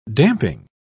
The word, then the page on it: damping: British English